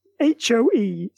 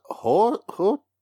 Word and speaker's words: Hoechlin: It's, yeah, so this is Hoechlin.